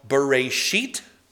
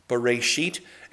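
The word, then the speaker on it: Bereshit (בראשית): It's pronounced Bereshit. Bereshit, and it means in the beginning.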